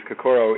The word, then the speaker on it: Kokoro: Yeah, the actual camp and the word Kokoro, and that's kind of the pronunciation we use, Kokoro, it means heart or warrior spirit or heart-centered spirit.